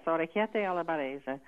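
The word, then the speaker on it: orecchiette alla barese: So orchiette alla barese? – Yes, orchiette alla barese.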